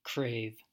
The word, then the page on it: crave: American English